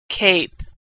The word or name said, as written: cape